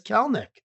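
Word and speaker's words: Kelenic: It says Kelnick. – In the Seattle pronunciation? – That is the Seattle pronunciation.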